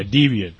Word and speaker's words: Debian: How do you say that? – Deviant. – Yeah, Debian.